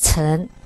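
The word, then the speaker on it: 琛: So I checked the dictionary, and the pronunciation is Chen.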